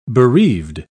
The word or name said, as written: bereaved